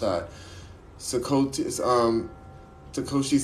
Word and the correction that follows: Satoshi: Sakanam. Uh, yes, um, Satoshi. There you go, Satoshi.